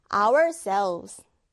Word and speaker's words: ourselves: 우리들 자신을 할 때는요, ourselves, self가 아니라 selves. 발음 꼭 하셔야 돼요. ourselves.